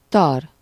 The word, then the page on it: chauve: /ʃov/